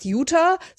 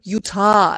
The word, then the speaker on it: Utah: Und man lernt, wie man, das ist nicht, wie die Deutsche sagt, Utah, sondern Utah. Also ich kann es gar nicht Ah.